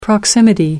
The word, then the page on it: proximity: American English